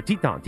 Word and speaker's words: Teton: Blue Ice Vodka made from Idaho potatoes and pristine Teton water. Oh, no, Teton. Teton water. – I mean, the Teton. It is French if you say it correctly.